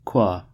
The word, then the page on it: qua: American English